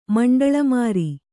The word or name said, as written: ಮಂಡಳಮಾರಿ